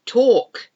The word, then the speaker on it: talk: S-A-L-M-O-N, and I like salmon. I like to eat salmon. Talk. We're talking about all these words. We're talking, but we don't pronounce the L in talk. It's T-A-L-K, talk.